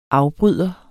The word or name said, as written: AFBRYDEREN